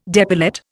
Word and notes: depilate: Indian

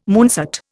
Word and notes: moonset: Indian

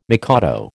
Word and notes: mikado: American English

